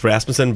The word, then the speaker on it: Rasmussen: Well, you can say Rasputin. – Rasmussen. – Just put a curse on you. It's Rasmussen, but...